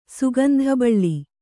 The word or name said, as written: ಸುಗಂಧಬಳ್ಳಿ